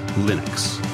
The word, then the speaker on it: Linux: Leenux. – Leenux. – Leenux. – Leenux. – Leenux. Or Leenux. – Leenux. – Leenux. – Leenux. – Leenux. – Let's get one thing straight. It's pronounced Leenux.